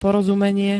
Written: porozumenie

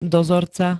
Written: dozorca

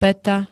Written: pata

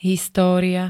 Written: história

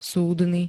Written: súdny

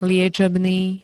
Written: liečebný

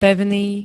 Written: pevný

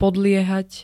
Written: podliehať

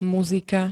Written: muzika

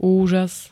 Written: úžas

